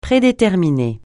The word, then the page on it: prédéterminer: francais